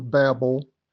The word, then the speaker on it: Babel: or at the Tower of Babel, or Babel, if you prefer that pronunciation.